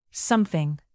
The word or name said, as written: something